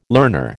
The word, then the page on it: learner: American English